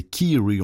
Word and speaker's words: Kyrie: Tout à l'heure j'ai dit Kyrie. Non, c'est Kiri, on l'entend bien, il le prononce comme ça, hein, Kiri. K-Y-R-I-E, Kiri.